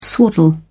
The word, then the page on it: swaddle: British English